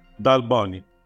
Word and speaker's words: Dal Boni: Del Boni.